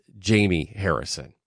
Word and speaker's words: Jamie Harrison: During the Work Shift Live segment, we mispronounced the name of the Democratic National Committee Chair. That's Jamie Harrison.